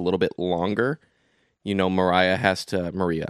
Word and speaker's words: Maria: I've been calling her Maria. Then I, all of a sudden, I started hearing all this nonsense that is pronounced Mariah. It's not.